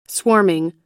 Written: swarming

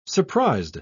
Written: surprised

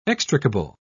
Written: extricable

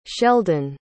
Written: sheldon

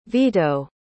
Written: vito

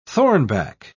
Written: thornback

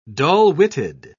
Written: dull-witted

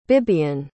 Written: bibiane

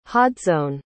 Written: hodsone

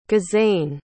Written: gizane

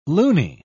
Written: loony

